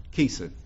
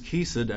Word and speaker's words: chesed: And it's spelled C-H-E-C-E-D, C-H-E-C-E-D. And it's pronounced chesed, like a key, like you'd put a key in a door, chesed. Strong's defines chesed as piety.